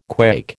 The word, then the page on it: quake: American English